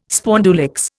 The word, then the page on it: spondulicks: Indian